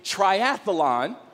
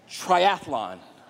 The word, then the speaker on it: triathlon: Most of us add a syllable that isn't there pronouncing it triathlon, which I do. It's actually triathlon.